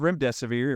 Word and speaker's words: remdesivir: Well, if you go back and watch all my interviews, I actually told everybody the drug is called remdesivir. It rhymes with run, death is near.